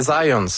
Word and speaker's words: Zajonc: J'ai-tu pas dit Zajonc? – Ben, j'avais dit plus Zajonc, mais... – Je l'ai prononcé un petit peu à l'anglais. J'ai prononcé mon polonais à l'anglais.